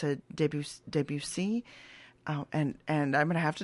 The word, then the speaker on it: Debussy: Oh, Debussy.